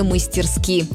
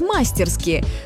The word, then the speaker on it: мастерски: Сегодня целый ряд современных словарей допускает ударение на первый слог «мастерски». Большинство из них помечает этот вариант как разговорный, то есть в быту, пожалуйста, сколько угодно.